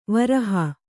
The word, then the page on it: ವ್ರೀಹಿ: vrīhi